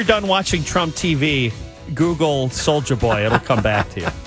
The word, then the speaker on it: Soulja Boy: Am I saying it right? – Yeah, Soulja Boy.